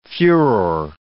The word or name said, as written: furore